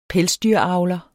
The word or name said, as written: pelsdyravler